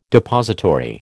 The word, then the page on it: depository: American English